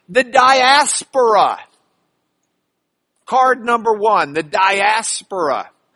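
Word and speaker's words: diaspora (διασπορά): The Greek word is diaspora. They just pronounce it a little bit differently, diaspora.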